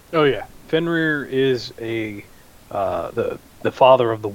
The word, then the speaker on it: Fenrir: Now, I have that as the story of, how do you say, Fenrir? – Fenrir.